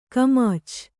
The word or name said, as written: ಖಮಾಚು